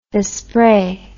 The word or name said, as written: vespre